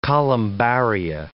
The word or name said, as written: columbaria